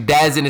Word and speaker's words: DAZN: So DAZN, Matchroom Boxing, seems as if what they're going to go ahead and do, or actually DAZN is pronounced DAZN.